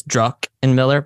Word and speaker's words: Druckenmiller: I always pronounce it, it's Druckenmiller, but I always, I call it Druckenmiller by accident.